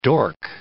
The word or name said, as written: dork